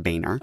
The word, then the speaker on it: Boehner: It's John Boehner. – Yeah. Actually, his last name is pronounced Boehner.